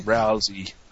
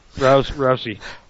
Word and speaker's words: Rousey: Who's Rousey? – Or whatever. – Rousey? – Rousey. – Yeah. Rousey.